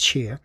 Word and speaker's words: Tchir: And before that, Tre- Trevor Tchir, uh, T C H I R, and I'm pretty sure it's pronounced Cheer.